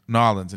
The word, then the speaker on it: New Orleans: Or the correct pronunciation, New Orleans.